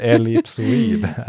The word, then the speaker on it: ellipsoid: Så Jens, han skickade mig en hänvisning till Svenska Akademins ordlista med hur man uttalar ordet ellipsoid. – Ja. – Så det, förra avsnittet hade lite blandade uttal där. Men ellipsoid är det rätta svaret på fråga 4.